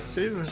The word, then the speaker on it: Saviour: Savior.